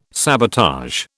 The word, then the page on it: sabotage: British English